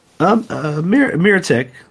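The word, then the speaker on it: Mirotić: So Nikola Mirotic, how do you pronounce his name? You would know. – Mirotic. That's how I usually say it.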